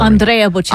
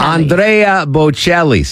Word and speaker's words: Andrea Bocelli: Andrea Bocelli. – Andrea Bocelli.